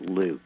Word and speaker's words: LIWC: Well, this computer program is LIWC, and we pronounce it Luke. And I know it doesn't look like Luke, but what it does is it's a really remarkably simple program.